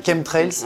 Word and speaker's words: chemtrails: Chemtrails.